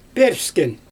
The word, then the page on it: pööpsken: Xyzyl